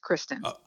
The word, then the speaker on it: Kristen: You know, that's what old ladies at church tend to call me, but nope, it's phonetically spelled Kristen, just Kristen.